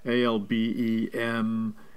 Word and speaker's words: Albemarle: It's a company called Albemarle. I believe that's the right pronunciation. Albemarle. – Yep.